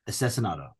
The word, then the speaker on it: Assassinato: Like, how do you pronounce it properly? How do we do it? – Assassinato. – Assassinato? Cool. So it is straightforward.